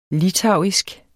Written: litauisk

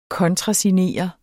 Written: kontrasignere

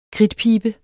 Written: kridtpibe

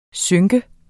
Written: synke

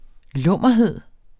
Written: lummerhed

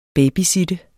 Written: babysitter

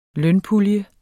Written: lønpulje

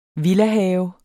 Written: villahave

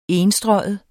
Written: enstreget

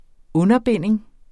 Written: underbinding

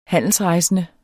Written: handelsrejsende

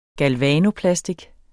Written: galvanoplastik

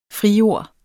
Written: frijord

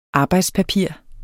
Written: arbejdspapir

